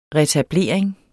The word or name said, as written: retablering